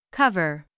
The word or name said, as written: cover